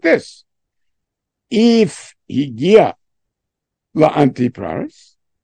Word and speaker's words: Antipatris: We usually call it Antipatris.